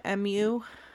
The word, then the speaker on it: Mu: It's Mu, M-U. – Yeah. Like, it's pronounced like the cow pronunciation, Mu. – Mu. Well, it's the, right? The Greek letter Mu.